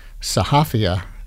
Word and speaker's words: sahafia: And you use the word sahafia in your introduction for the anthology. Correct my pronunciation of that word if it needs it. – It's perfect.